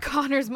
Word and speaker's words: Connagh: Did Connor's mum stub her toe while she was naming him? Because it's spelled C-O-N-N-A-G-H. Connor.